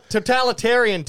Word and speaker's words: totalitarian: We see NFL owners do what I like to call the totalian tiptoe, which I don't even know if I said that right. But what I mean to say is totalitarian tiptoe.